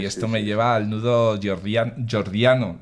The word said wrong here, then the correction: Gordiano: ¿lo he dicho bien? Jordiano. – Gordiano, Gordiano, eso.